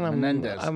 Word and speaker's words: Menendez: Menendez.